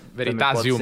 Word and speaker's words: Veritasium: Veritaserum. – Non so come pronunciare. È americano, tanto Veritaserum. – Veritasium.